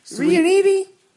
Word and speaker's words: Riunite: That's R-I-U-N-I-T-E. – Riunite? – Well, you don't pronounce the E. It's Riunite.